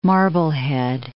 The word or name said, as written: Marblehead